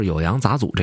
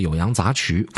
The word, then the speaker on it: 酉阳杂俎: 关于这事儿呢，我需要解释两个地儿，一个是《酉阳杂俎》这个事儿，我上一回读错字了，我读成了《酉阳杂曲》。